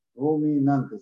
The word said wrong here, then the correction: rumiante: se dice así en español, ¿no? Rumiante.